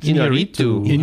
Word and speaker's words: Iñárritu: Iñárritu. – Alejandro Iñárritu for Birdman.